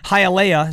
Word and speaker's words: Hialeah: Hialeah. Is that how you pronounce that? Hialeah? Yeah, in South Florida.